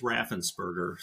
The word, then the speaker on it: Raffensperger: Well, my last name is Raffensperger, so it's a long name, but it's spelled exactly like it sounds, or sounds exactly like it's spelled.